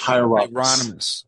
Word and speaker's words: Hieronymus: Hieronymus? – Galen Hieronymus. Hieronymus.